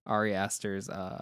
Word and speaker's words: Ari Aster's: And the first movie I bought to go with it for him to watch it was Hereditary, Ari Aster's, I'm probably mispronouncing that name, but no, that's right.